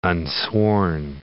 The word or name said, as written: unsworn